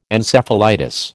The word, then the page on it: Encephalitis: American English